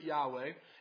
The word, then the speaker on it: Yahweh: So because it's so important and so special to God's people, we actually don't perfectly know how to pronounce the name Yahweh. We can have confidence that you do pronounce it Yahweh, and yet we don't know for sure. And that's because the Hebrew Bible was actually written with consonants only.